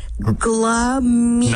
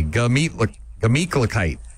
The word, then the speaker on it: Gemütlichkeit: Glah meat. – Not a glah. Gah. Just G-A. Gemütlichkeit.